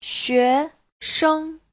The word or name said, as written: xue2sheng5